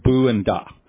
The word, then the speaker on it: Buddha: So a Buddha is someone, this Buddha is a Sanskrit term, and it has two syllables, Bu and Da.